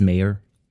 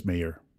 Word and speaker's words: Maher: Vi snakker om Dennis Maher. – Dennis Maher. – M-A-H-E-R. – Okay, Maher. – Maher, ja, nej, Maher.